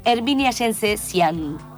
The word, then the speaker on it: Herminia Jensezian: Y a propósito de ello, estamos hablando con su puestista y directora general, Herminia Yense-Sian. Buenas noches, Herminia. ¿Estás ahí? – Sí, aquí estoy. Buenas noches. – Hola, Herminia. ¿Lo pronuncié bien? – Sí, todo perfecto.